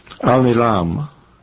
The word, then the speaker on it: Al-Nilam: Al-Nilam. Al-Nilam. Mm-hmm. That's an Arabic word. You have to come down hard on the last syllable.